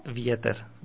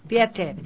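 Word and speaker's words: Vieter (Ветер): Claro, se escribe Vemep y se pronuncia Vieter. – Vieter. Que quiere decir viento.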